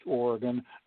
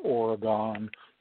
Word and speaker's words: Oregon: It's pronounced Oregon, not Oregon.